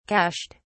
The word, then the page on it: cached: British English